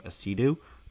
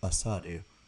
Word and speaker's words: Asadu: Nana Asadu, which side note, I've always said his name Asidu. The depth chart comes out and it tells you the phonetic pronunciations. – You pronounce his last name Asadu. Like, Sa, Remember that video?